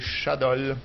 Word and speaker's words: Shadols: Chadols. Ça s'écrit S-H-A-D-O-L-S, les Chadols.